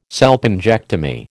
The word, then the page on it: salpingectomy: American English